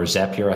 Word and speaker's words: Zapier: So you could connect a form on your Carrd website with Zapier uh, or Zapier. I have to say Zapier because it rhymes with happy.